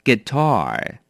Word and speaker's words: guitar: 第六个字 guitar 名词吉他，这个字要小心，它的发音是 guitar，重音在后面，而且不要忘了 tar 要稍微卷一下舌。